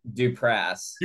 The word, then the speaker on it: Duprass: a bill with the Martin Duplass, um, that is uh, that's that's the comedy duo. Or Am I saying it right? Duprass. Duprass. That's ah, the Martin Duprass. It's um, a Kurt Vonnegut word.